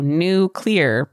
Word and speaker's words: Newclear: It's spelled N-E-W-C-L-E-A-R. So Nuclear, but it's when you say it fast, it sounds like Nuclear.